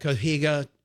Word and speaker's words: Cotija: So I just ran in the back, grabbed some corn, grabbed this herb tahini, threw some onions instead of cotija. How do you say it, Christian? Cotija cheese.